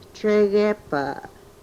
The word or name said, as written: tregepa